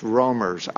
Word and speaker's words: roamers: I pronounced that roamers. It came out rumors. It's roamers, R-O-A-M-E-R.